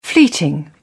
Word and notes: fleeting: British English

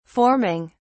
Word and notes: forming: British English

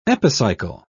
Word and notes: epicycle: British English

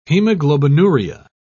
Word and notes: hemoglobinuria: British English

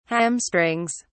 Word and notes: hamstrings: British English

